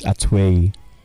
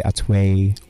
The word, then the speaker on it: Atoye: Okay, so it's Atoye. That's how you say it. – Okay. – Obviously, if you have an accent, you know, in the UK, they say Atoye or Atoye. you know, so it's fine.